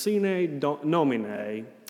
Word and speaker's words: Sine Nomine: When you open your hymn book, you'll see at the bottom of the hymn book, the name of that tune is pronounced Sine Nomine. It's a Latin word that means without a name.